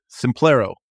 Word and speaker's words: Simplero: Simplerio. S-I-M-P-I-E-R-O. – Simplero. – Simplero. How do you pronounce it? – I think it's called Simplero. – Yeah, Simplero.